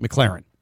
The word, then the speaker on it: McLaurin: No, it's Terry McLaurin. It's pronounced McLaurin.